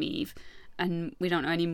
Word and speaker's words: Yves: So Eve is named after Sylvain's brother, Eve. It's spelt like Yves Saint Laurent. – Yes. – Y-V-E-S.